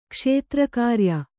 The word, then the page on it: ಕ್ಷೇತ್ರಕಾರ್ಯ: kṣētra kārya